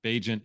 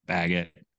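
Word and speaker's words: Bagent: Bajent is how it's pronounced. I got some intel. I finally realized maybe we need to actually learn how to pronounce this guy's name. We call him Baggett. Call him other things. But yeah, it's Bajent as an agent.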